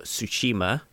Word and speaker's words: Tsushima: Um, Sucker Punch are developing a what looks like a samurai action game um, called Ghost of Tsushima um, with the silent T.